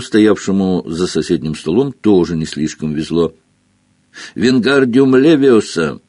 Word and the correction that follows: Вингардиум левиоса: «Ты неправильно произносишь заклинание!» — донесся до Гарри недовольный голос Гермионы. «Надо произносить так! Вингардиум левиоса! В слоге «гар» должна быть длинная «а».